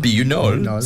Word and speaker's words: Buñol: Thousand Scanner in Buñol, is that how you say it? – Buñol, Spain, for the world's largest food fight.